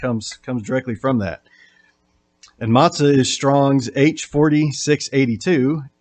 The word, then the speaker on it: matzah: And that Hebrew word, best I can tell from the transliteration, is pronounced matzah.